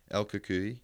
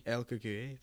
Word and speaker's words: El Cucuy: El Cucuy. – El Cucuy, the boogeyman.